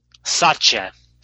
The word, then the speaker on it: Satya: Satya.